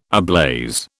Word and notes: ablaze: British English

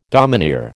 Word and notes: domineer: American English

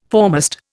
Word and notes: foremast: Indian

